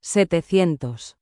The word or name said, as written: 700